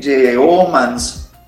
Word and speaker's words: Yeomans: Eh, en Instagram, en todas mis redes sociales, yo estoy, ahí directamente en Instagram, Facebook, YouTube, es Yeomans Music. Se escribe Y y luego, y luego es una E, Yeomans y-E-O-M-A-N-S.